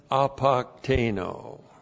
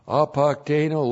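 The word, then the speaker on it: apokteno (ἀποκτείνω): And then apokteno. Apokteno looks like that in the Greek. A-P-O-K-T-E-I-N, long O. Apokteno.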